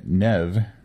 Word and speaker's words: Niamh: Together with A's companion, Nev, which is spelled N-I-A-M-H, but according to the book, that's how you pronounce it,